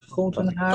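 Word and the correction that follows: Thornton Hough: Thornton Huff. – Thornton Huff, yes, I thought it might be plural.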